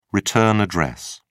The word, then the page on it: return address: British English